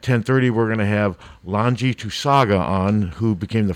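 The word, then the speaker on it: Laulauga: It's like LAWN-GE, Lanji, and then last name is Tusaga.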